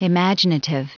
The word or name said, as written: imaginative